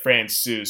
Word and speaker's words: Francouz: You could say Pavel Francouz, Pavel Francouz, but like when I've asked him about it, he says like Francouz, so it's kind of like the C-O-U-Z is almost like Souza, except for it's just Souz.